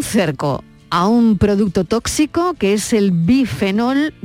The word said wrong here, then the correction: bisfenol: bisfenol, lo digo bien, bisfenol A, que está presente Estivaliz en un montón de envases.